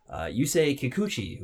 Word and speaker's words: Yusei Kikuchi: Yusei Kikuchi, who is 27 years old.